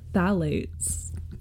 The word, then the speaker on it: phthalates: Flatulates. P-H-T-H-A-L-A-T-E-S.